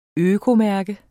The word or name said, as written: økomærke